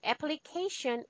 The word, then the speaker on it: application: Okay, application is the applies noun, application, right? Accent change. Apply. Repeat after me. Apply. – Apply. – Application. – Application.